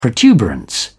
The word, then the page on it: protuberance: British English